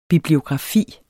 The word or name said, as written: bibliografi